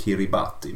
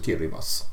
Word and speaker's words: Kiribati: Eh, och om man inte känner igen namnet Kiribas så skulle det kunna bero på att det stavas Kiribati men det uttalas alltså Kiribas.